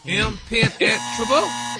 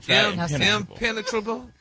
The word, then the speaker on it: Impenetrable: Impenetrable. – No, your emphasis Rock. Is on the wrong syllable.